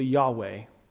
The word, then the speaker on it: Yahweh: First of all, God's personal name, we believe, is pronounced more closely Yahweh.